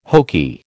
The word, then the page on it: hokey: American English